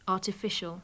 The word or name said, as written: artificial